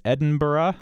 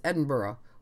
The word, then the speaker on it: Edinburgh: The capital of Scotland, E-D-I-N-B-U-R-G-H, is Edinburgh. – That's kind of, yeah. From what I found, more Scots don't do burrah. It's more like bruh. It's more one syllable, and it can be two syllables, but they're again short syllables. So it's Edinburgh or Edinburgh. – All right. – It doesn't. It's not. Does not rhyme with Pittsburgh.